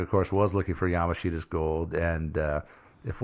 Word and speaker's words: Yamashita's: But as for where he got these, well, this is the Philippines, you know, Yamashita's gold and all that stuff.